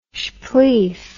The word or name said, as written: shpleef